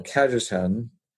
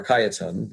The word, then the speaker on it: Cajetan: What he's rejecting is a concept of analogy which is used by early modern Thomists that you find, for example, in Cardinal Cajetan, or Cajetan, but some people pronounce it either way, and then a guy by the name of Francisco Suarez.